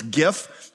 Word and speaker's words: GIF: There's a little controversy about how to pronounce this one. I think it's GIF because it's graphic interface format, and that would make sense.